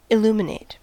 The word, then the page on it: illuminate: /ɪlˈlumɪneɪt/; /ɪlˈluməneɪt/